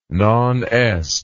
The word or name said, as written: nonest